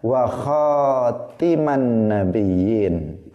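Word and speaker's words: وخاتم النبيين: Dalam qiraah yang lain itu dibaca wa khatiman nabiyyin di kasrah taknya. Nah, yang umum itu qiraah yang kita anut wa khataman nabiyyin. Tetapi ada jalur jalur qiraah lain yang membacanya wa khatiman nabiyyin. Kalau wa khatiman nabiyyin maka artinya adalah akhir para nabi.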